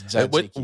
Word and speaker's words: tzatziki: It's not tzatziki. It's tzatziki.